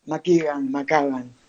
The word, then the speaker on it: McKagan: McKagan.